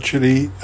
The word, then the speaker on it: Chile: I don't know how you pronounce that, Chile. It says it's a phonetic approximation of child without the D.